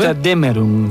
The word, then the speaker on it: Götterdämmerung: Götterdämmerung.